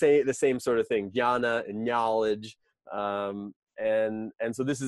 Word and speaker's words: Jnana: And then the last is Dhyana Yoga, which is spelled J-N-A-N-A. And that J-N, it's similar to like our word knowledge starts with that K-N.